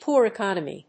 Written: poor economy

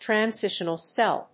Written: transitional cell

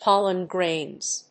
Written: pollen-grains

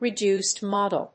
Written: reduced model

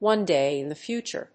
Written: one day in the future

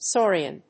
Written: saurian

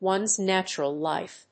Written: one's natural life

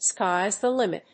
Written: sky's the limit

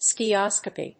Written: skiascopy

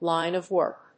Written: line of work

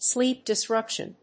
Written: sleep disruption

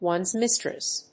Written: one's mistress

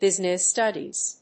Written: business studies

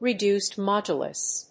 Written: reduced modulus

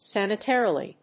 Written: san・i・tar・i・ly

